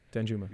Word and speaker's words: Danjuma: Dunjuma? Am I pronouncing this right? From Netherlands? – Sure. Danjuma.